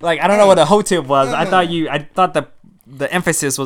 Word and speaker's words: hotep: You know, I didn't even pronounce it that way in my head. – What did you think it said? – It was like a hot tip.